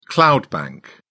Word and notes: cloud bank: British English

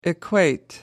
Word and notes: equate: American English

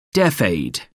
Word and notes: deaf-aid: American English

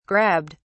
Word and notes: grabbed: British English

